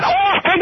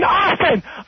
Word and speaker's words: often: Not often, often! Often with a short O, often!